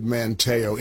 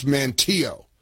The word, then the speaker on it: Manteo: Incidentally, I knew I was mispronouncing that as soon as I said Manteo. It's Manteo. Sorry.